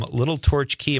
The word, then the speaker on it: Little Torch Key: That's Steve from Little Torch Key.